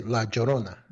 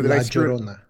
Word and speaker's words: La Llorona: La Lorna. Did I pronounce that right? Or I totally screwed it up. – Oh, Oh, you're trying to say La Llorona.